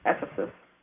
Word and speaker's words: Ephesus: How do you pronounce that? Ephesus? – Ephesus. Mm-hmm.